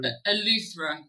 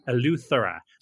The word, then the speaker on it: Eleuthera: Eleuthera. – Eleuthera.